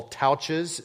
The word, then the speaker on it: Tautges: It's by Paul Touches. It sounds like couches. It's spelled T-A-U-T-G-E-S, Touches.